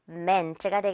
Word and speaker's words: meant: 자, 두 번째로 이것도 mint 아니겠죠? Meant가 되겠습니다.